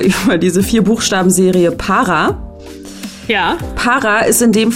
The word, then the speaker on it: para: Para. Para eigentlich. Dann habe ich es auch noch falsch ausgesprochen.